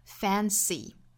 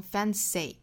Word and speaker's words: fancy: 我们放到单词里面，然后注意语调，注意弱读 fancy，不要读成 fancy，好，应该是 e 紧音。